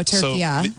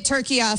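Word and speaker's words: Türkiye: Turkey-a. I will be saying it turkey-a from now on.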